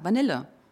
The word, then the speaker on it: Vanille: Ja, würdest du es auch so aussprechen? – Ja. – Vanille, ne? – Ja. Würde ich auch sagen, Vanille.